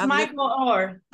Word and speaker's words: Michael Oher: It's Michael Oher. – I thought it was Michael, but I didn't want to say it wrong. Michael Oher.